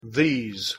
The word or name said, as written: these